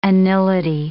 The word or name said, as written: anility